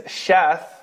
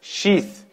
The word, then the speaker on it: Sheeth: According to some narrations, the pronunciation is Shath. But the more common pronunciation, which is accepted by most scholars, is Sheeth, who later on became a prophet, alayhi salam.